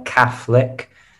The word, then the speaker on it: Catholic: Remember the pronunciation here? Catholic.